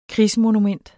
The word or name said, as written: krigsmonument